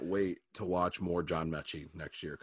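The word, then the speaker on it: Metchie: Did you see that block by your boy, was it Mincy? How do you say his name? The wide out for Bama. – John Mechie. – Mechie.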